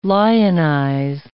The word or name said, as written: lionize